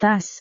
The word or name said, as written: tas